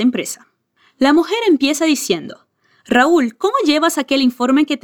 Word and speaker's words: Raúl: La R es vibrando la punta de tu lengua y la L es con la punta de tu lengua detrás de tus dientes superiores. Raúl. Raúl.